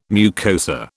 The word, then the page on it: mucosa: British English